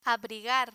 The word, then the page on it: abrigar: spanish